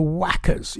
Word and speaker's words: Whackers: And the Whackers, yes, I did say it right, W-H-A-C-K-E-R-S, the Whackers and the Girl Who Wanted Fame.